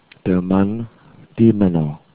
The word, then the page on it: mann: german